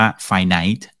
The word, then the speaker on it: finite: มันก็ไม่ได้อ่านว่าฟินิตใช่ไหมมันก็อ่านว่าไฟไนท์